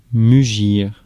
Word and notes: mugir: France; [my.ʒiʁ]

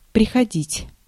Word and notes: venir: France; /və.niʁ/

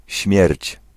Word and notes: end: /ɛnd/

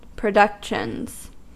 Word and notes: productions: American English

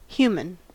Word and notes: human: [ˈhju.mən]; /ˈhjuː.mən/

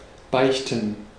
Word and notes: confess: /kən.ˈfɛs/

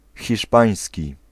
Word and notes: hiszpański: [xʲiˈʂpãĩ̯s̪ʲkʲi]; /çiʃˈ.pãj̃sʲ.ci/

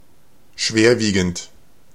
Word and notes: serious: American English; [ˈsɪər.iəs]

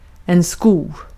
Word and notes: profit: [ˈprɒ.fət]; American English

